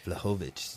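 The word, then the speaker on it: Vlahovich: V as in Victor, L-A-H-O. Victor I-C-H. – Dude, I never would have guessed. – Vlahovic. – Vlahovic.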